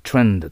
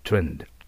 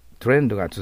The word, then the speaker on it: trend: トレンドでは通じません。 T の後に母音を残しちゃダメです。 TR だから。 trend と発音します。 trend。この傾向が、トレンドが続くと思いますか ?Do you think this trend will continue?